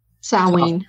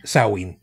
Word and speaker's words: Samhain: How is it pronounced again? Samhain? – Samhain. – Samhain. – Halloween. – See, I've always pronounced it the way that I had seen it. I had not. – Like Samhain. – Yeah. – Yeah. – Yeah, because I didn't know. You know, I speak American and Brooklynese, otherwise known as Vulgar. I have learned a little bit of Spanish, so being able to properly pronounce it Samhain, I did not know, because I was reading it the way that, you know.